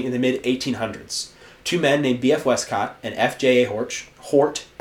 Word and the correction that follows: Hort: F.J.A. Hort, H-O-R-T. I don't know why I said that wrong.